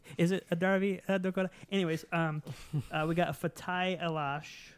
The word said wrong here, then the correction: Alashe: Probably not saying that right. – Alashe. – Alashe. Oh, man. I went for the silent E.